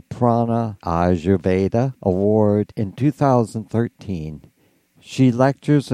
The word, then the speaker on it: Ayurveda: It's pronounced Ayurveda.